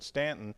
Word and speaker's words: Staunton: But Stanton, and if you look on a map, it's spelled S-T-A-U-N-T-O-N, and most people will pronounce it Staunton. And if you're from Stanton, they will correct you very quickly.